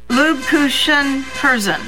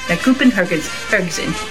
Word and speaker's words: Lebkuchenherzen: Lebkuchen Herzen. – The Kupenhergens Herzen.